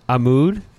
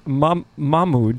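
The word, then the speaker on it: Mahmoud: Amud? – No, actually, it's Mahmud.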